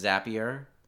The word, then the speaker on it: Zapier: If you've, essentially, if you know the web service Zapier, I think that's how you say it.